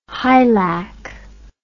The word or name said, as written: hilac